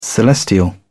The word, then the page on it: celestial: British English